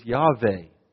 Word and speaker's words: Yahweh: They taught us Englishers that there's not a hard J sound in the Hebrew language. It's a Yah sound. And the best way to say this is Yahweh.